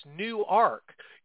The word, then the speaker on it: Newark: It's not Newark. It's Newark. You're going to learn how to pronounce everything differently when you go up there. It's Newark. And, Newark? – Yeah, okay.